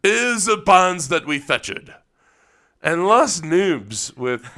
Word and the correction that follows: Las Nubes: It's pronounced Las Nubes.